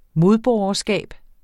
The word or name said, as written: modborgerskab